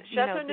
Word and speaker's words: Châteauneuf-du-Pape: Châteauneuf-du-Pape, am I saying this correctly? Help. – Yes, Châteauneuf-du-Pape.